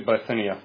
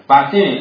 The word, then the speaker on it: Bithynia: Essayed to go to Bithynia. – Bithynia, thank you.